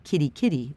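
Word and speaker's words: Kerikeri: I'd booked a flight to get me as close to the start of the trail as I could, a place called Kerry Kerry, pronounced Kitty Kitty by locals.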